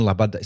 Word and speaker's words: Loboda: I go on Instagram a while ago for my favorite, Ukrainian singer, Svetlana Labada. So her name is Loboda. That's how you say it. L-O-B-O-D-A, but you say it Labada.